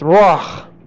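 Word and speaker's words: rah (רע): There is an here, and an alien in Greek is not pronounced. So this is pronounced rah.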